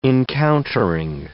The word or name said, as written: encountering